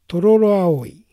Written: Tororoaoi

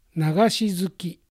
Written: Nagashizuki

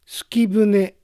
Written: Sukibune

